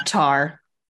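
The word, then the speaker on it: Tár: I want to hear your thoughts and opinions on, correct me if I'm saying this wrong, Tar? – Oh, God. Yeah, Tar.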